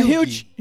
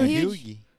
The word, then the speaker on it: Hugh: Como é que fala o nome é, dele? É Hugh. É Hugh. Hugh. Hugh. É, que é, é o nome irlandês.